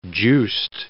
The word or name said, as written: juiced